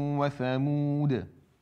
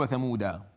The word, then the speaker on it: ثمود: فكلمة ثمود فتحة فقط، هذه الألف التي بعدها لا تلفظ في قراءة حفص عاصم لا وصلا ولا وقفا، فبتقول إذا وقفت وعادا وثمود ما تقول وثمودا، تمام؟